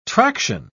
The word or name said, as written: traction